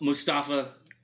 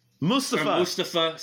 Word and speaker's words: Mustafa: I don't know a lot about Mustafa. – Mustafa, sorry. Yes. – Pardon me. – By the way, shout out for pronouncing Mustafa.